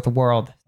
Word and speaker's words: world: It's actually pronounced at the back of the throat. So we have that also with world. That's really difficult for most learners because it has an R sound and a dark L sound.